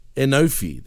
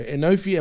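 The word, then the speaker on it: Enofe: how do you say his last name, man? – Enofi. – There you go. Enofi and Malik Blade show up to say...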